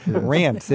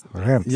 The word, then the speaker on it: Reims: Rance. It rhymes with France. – Then that's why I just had to take the ticket back and said, look, we got to do this in English. I'm sorry. – R-E-I-M-S. How do you pronounce that, Jean-Benoit? – Rinse. Yes, like a rinse.